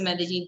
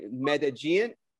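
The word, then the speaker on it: Medellín: No, I think it's the two L's. It gets everybody. Medellin. So like the double L is like, you know, we kind of say Medellin.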